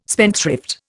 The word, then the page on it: spendthrift: Indian